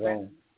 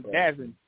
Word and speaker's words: DAZN: Is that how it's pronounced? – Dazone. Yeah. – Oh, I was calling it Dazin.